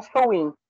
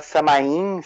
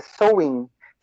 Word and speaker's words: Samhain: Os praticantes aqui no Brasil, os wicanos, falam Sowin. A pronúncia celta é Sowin, tá? O, o pessoal aqui no Brasil fala Samain,